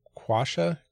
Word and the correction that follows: Quechua: Let's see, there's pronunciation. – Quechua. – Oh my gosh.